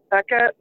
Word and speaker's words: Bekah: You can find me on Twitter under Becca, B-E-K-A-H, Boodles, B-O-O-D-L-E-S.